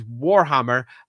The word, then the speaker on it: Warhammer: But do be aware, this is Warhammer, and I will stress the first syllable of that, war.